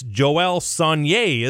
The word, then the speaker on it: Sonnier: They actually called me and they said, how do you say his name? And I said, it's Joel Sonnier, isn't it? And they said, yeah, you got it right.